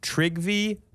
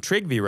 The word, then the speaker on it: Trygve: In 1989, a Norwegian citizen named Trygve Bauch, I might be pronouncing the last name right, but I'm pronouncing Trygve right because— Correct, because of our friend Chell.